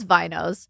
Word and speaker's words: vinos: Wait, it's off the vine, so it's winos? – No, it's vinos.